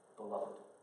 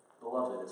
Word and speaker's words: beloved: But even more than that, the second label that God gives to us is this, that you are beloved. Beloved is how you pronounce that, not be loved, like be dazzled.